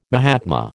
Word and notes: mahatma: American English

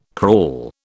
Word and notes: crawl: British English

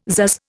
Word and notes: pzazz: Indian

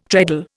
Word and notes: treadle: Indian

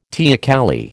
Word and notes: teocalli: American English

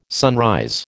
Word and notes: sunrise: American English

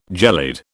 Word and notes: gelid: British English

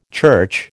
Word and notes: church: American English

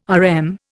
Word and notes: RM: Indian

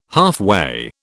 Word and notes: halfway: British English

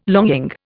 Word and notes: longing: Indian

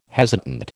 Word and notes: hesitant: American English

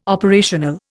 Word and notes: operational: Indian

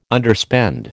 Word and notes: Underspend: American English

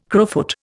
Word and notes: crowfoot: Indian

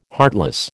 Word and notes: heartless: American English

